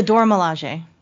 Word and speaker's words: Dora Milaje: It's like the Anoje. I don't even want to try to pronounce it. – It's the Dora Milaje. – Yeah. Well, basically that's the Dora Milaje is based off of this old, like, you know, historical group of women.